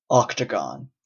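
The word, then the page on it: octagon: Canadian English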